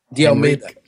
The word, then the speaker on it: De Almeida: So, it's Henrique with a very short E on the end, and De Almeida.